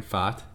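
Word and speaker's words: Pfaadt: That's how you pronounce it, Brandon Fott.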